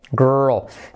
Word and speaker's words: girl: Girl. Especialmente complicada por la pronunciación porque tenemos tres letras claves seguidas. ¿Quién da más? La I como E. G. La R. R. Y la L. Girl.